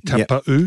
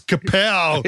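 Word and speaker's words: Taupau: I asked him, I said, Marty, did you change your name? He goes, no, you just pronounced it wrong. I said, well, for 10 years, I was Kapow.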